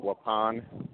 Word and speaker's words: Waupun: Yeah, that's probably the indigenous pronunciation, but the white people around here call it Waupun.